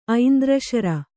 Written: ಐಂದ್ರಶರ

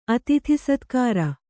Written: ಅತಿಥಿಸತ್ಕಾರ

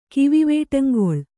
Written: ಕಿವಿವೇಟಂಗೊಳ್

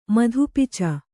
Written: ಮಧುಪಿಚ

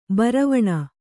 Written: ಭಾರವಣೆ

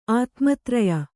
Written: ಆತ್ಮತ್ರಯ

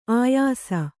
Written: ಆಯಾಸ

